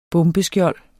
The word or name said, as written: bombeskjold